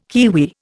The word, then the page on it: kiwi: Indian